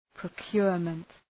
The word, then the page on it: procurement: prəʋk'jʋrmənt